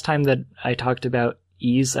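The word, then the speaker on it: Ys: Yeet. – Yeez. – Yeez, it's Yeez. It's the one that's spelled Y-S.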